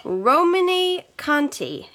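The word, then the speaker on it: Romanée-Conti: Romane Conti.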